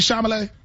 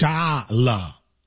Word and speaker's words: Chalamet: Is it Timothy Chalamet? – He said Charlamagne.